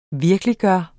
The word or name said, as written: VIRKELIGGJORTE